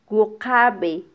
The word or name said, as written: wuqabe